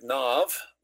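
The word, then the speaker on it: Nob: They're in covenant with one another, but David has to flee Saul, And he arrives in the city of Nov, spelled with a B, but that B becomes a V in Hebrew.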